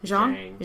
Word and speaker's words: Zhang: And the Zong brothers, how do you say it? Zhang? Zhang? Oh, yeah. I was way off.